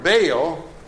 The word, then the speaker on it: Baal: The word Baal, in Hebrew pronounced Baal, okay, because there's two A's in there.